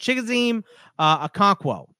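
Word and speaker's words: Chigoziem Okonkwo: Chigazima Okonowa. – That's not bad. I think it's Chigazim Okonkwo. I believe is how you say it.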